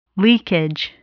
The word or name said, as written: leakage